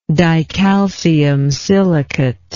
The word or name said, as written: dicalcium silicate